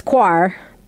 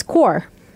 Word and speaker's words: quore: So I was thinking it's quar, but it's core. – Core? – Mm-hmm. – Q-U-O-R-E? – Mm-hmm.